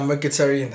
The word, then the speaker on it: Mkhitaryan: Yeah, Mkhitaryan. That's how you say it. I used to say Mkhitaryan.